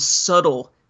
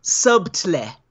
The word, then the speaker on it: subtle: I think the word subtle fits this film very well, at least relative to the other films. – Okay. – Or subtle, as I had someone pronounce it to me once.